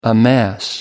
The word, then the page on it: amass: American English